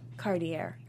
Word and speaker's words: Cartier: How do you say Cartier? – Cartier. – I've been saying it wrong my whole life.